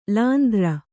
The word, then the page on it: ಲಾಂದ್ರ: lāndra; kannada